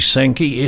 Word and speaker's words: Sandke: As I said before, if you've just joined us, this is Randy Sankey, S-A-N-D-K-E, is the way he pronounces his surname.